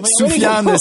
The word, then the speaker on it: Sufjan: Soufiane Stevens, S-U-F-J-A-N Stevens, comme euh, Stevens, comme vous le prononcez.